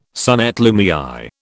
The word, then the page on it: son et lumière: British English